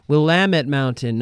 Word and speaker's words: Willamette: And, we were recording that song, and I remember Swift saying, it's actually pronounced, Neil's from the other room, it's actually pronounced Willamette Mountain, not Willamette.